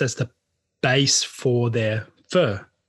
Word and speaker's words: pho: pho is fur. It's P-H-O. pronounced fur.